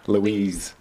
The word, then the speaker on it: Luis: How do you say Luis in British? – What does it look like? Louise. – Louise? – It's Louise, right? – Louise. Louise. – Louise. – Like with a Z.